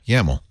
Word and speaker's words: YAML: Yeah, YAML.